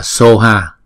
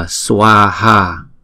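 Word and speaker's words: svāhā: Có khi á, các bạn thấy là chữ này người ta đọc là soha. Soha là từ phiên âm của tiếng Tây Tạng. Tiếng Phạn là chúng ta đọc là swaha, swa, swaha. Chúng ta đọc chậm là swaha, chúng ta đọc nhanh là swa, cũng giống như là swa đó.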